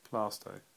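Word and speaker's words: Plaistow: What about Plaisto or Plasto? – Plasto.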